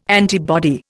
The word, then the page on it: antibody: Indian